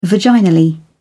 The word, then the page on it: vaginal: British English